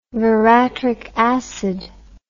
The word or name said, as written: veratric acid